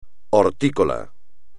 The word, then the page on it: hortícola: espagnol